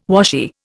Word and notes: washy: Indian

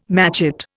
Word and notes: matchet: Indian

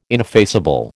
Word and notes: ineffaceable: American English

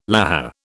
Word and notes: lahar: British English

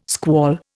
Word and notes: squall: Indian

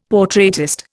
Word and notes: portraitist: Indian

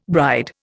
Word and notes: bride: Indian

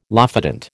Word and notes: lophodont: American English

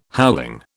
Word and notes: howling: British English

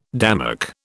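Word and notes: démarche: British English

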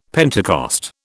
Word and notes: Pentecost: British English

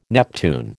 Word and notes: Neptune: American English

